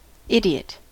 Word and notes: idiot: American English

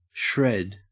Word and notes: shred: Australian English

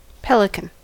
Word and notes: pelican: American English

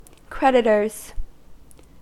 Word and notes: creditors: American English

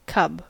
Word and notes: cub: American English

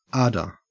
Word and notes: ardor: Australian English

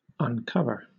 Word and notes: uncover: British English

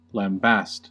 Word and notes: lambaste: American English

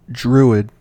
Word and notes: druid: American English

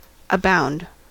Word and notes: abound: American English

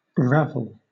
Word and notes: ravel: British English

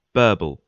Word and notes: burble: British English

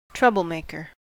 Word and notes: troublemaker: American English

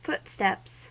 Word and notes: footsteps: American English

